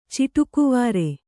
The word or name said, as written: ಚಿಟುಕುವಾರೆ